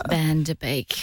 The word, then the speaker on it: Van Der Beek: No, I'm going to get the name right. The correction was an incorrect name. – Wow. – Right person and then pronounce the name wrong. – James Van Der Beek.